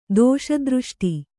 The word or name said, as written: ದೋಷದೃಷ್ಟಿ